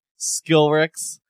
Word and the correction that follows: Skrillex: What did you say? Skillrix? – What is it, Skrillex.